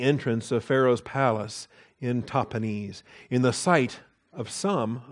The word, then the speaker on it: Tahpanhes: In Hebrew, it's Tahpanhes.